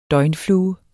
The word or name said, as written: døgnflue